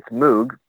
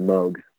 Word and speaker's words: Moog: So they decided really early on, let's just tell everybody, let's change the pronunciation to Moog. So for the duration of the company, when he finally started the company, it became more of a commercial entity. It was Moog for all intents and purposes. But technically, it's pronounced Moog. So, for example, they had a keyboard called the Rogue. That was one of their models. The whole point was for it to rhyme.